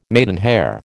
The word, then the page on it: maidenhair: American English